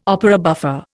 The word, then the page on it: opera buffa: Indian